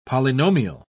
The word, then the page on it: polynomial: British English